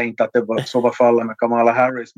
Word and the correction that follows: Kamala Harris: men att, eller Kamala Harris ska det väl uttalas, ja.